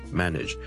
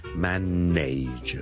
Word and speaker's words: manage: 萧叔今日想同大家讲 manage 呢个字嘅发音，manage M A N A G E manage。由于呢个字后面 N A G E 咧，nage 咧系串做 N A。好多人就会将它读成 manage 的，但是事实上我这个字是读 manage nudge nudge nudge，你当是好像 N-I-D-G-E 那样读 OK manage，